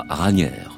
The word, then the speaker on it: Raniere: Qu'on appellera Ranier, par commodité aussi parce que son nom s'écrit à la française.